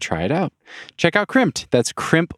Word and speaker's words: Crimpd: Crimped is spelled C-R-I-M-P-D. That's crimp with a D at the end,